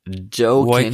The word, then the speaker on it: Joaquin: Und ich glaube auch nicht, dass äh, äh, wie spricht man ihn aus? Wen? Mr. Phoenix. – Joaquin. Joaquin. Ja, Joaquin. – Joaquin Phoenix. – Joking äh, ist ja falsch.